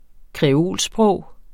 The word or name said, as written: kreolsprog